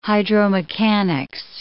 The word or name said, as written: hydromechanics